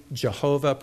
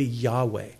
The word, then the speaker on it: Yahweh: which is pronounced most popularly Jehovah, probably most accurately Yahweh.